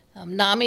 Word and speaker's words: NAMI: NAMI is NAMI, how you say it.